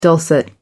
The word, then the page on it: dulcet: American English